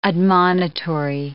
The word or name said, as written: admonitory